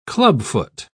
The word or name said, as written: clubfoot